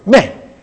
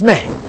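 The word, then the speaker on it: me (μή): Now, you see, the word not, when it says so do not, that word not is translated from a Greek particle that actually is a Greek particle, me. Just like, spell it like me, as we spell me in English. It's me.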